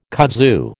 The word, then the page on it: kudzu: American English